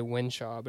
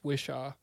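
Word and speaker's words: Whishaw: Sorry, I was about to say Winshaw, but it's Wishaw.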